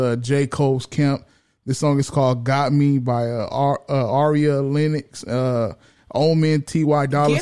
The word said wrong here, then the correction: Ari Lennox: Get her name right. Her name is Ari Lennox. – Ari Lennox. What I say? – You said Aria Lennox.